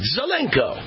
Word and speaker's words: Zelenko: The doctor I had on the show last week, Dr. Vladimir Zelenko, that's how he told me to pronounce it, Zelenko,